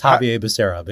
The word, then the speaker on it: Javier Becerra: When there's not, I would guess that 90% of Americans have not thought of, uttered, or would even recognize the name Xavier Becerra. – especially if it's pronounced that way, because it's Javier Becerra, but yes.